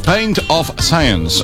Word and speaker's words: Pint of Science: a ver si lo digo bien, Pint of Science. – Aquí por aquí dicen que sí, que lo has dicho bien.